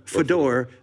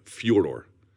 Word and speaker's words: Fyodor: My understanding is the proper pronunciation is something more along the lines of Fyodor, like a F-Y-O-D-O-R, rather than Fi or Fe, Fyodor.